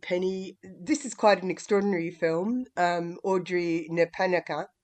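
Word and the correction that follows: Napanangka: I said it correctly? – It's Napananka.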